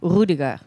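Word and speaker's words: Rüdiger: Like in German, you would pronounce it Rudiger.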